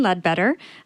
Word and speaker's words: Leadbetter: So my name is Lillian Leadbetter. It's spelled phonetically, and State of Romance is the album name.